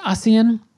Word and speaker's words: ASEAN: If I'm using the correct Bahasa dialect and accent, it is pronounced ASEAN.